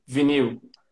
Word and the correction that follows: vinyl: The correct pronunciation is vinyl.